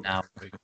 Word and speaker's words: Nauru: So Leicester lineup, Arsenal wage bill, Man City training complex, and the GDP of Nauru, which I think is how you pronounce it. Hopefully the Nauru listeners can correct me. – All of the Nauru listeners. – I think that's right, actually. I think you pronounced it correct.